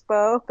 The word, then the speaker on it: Bowe: It was a play on my surname, because my surname's Bowe, but it's spelled B-O-W-E.